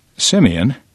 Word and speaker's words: Simeon: Simeon, whose name is pronounced like Simon, says seeing where his dad went to church was a good experience, as was spending so much quality time with his brothers.